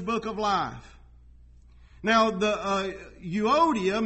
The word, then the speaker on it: Euodia: I urge Euodia, I look these up, and that's how you say it, Euodia.